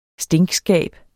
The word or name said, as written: stinkskab